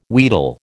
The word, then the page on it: wheedle: American English